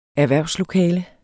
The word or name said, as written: erhvervslokale